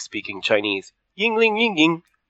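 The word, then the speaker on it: Yuengling: I'm drinking Yingling, or as my coworkers call it, Yang Lang.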